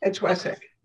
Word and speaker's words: Wessex: It's Wessex. – Okay. – It's the Wessex Festival. Thank you. – Okay. – Yeah, you spell it differently, but yeah, that's the way it's pronounced, is Wessex.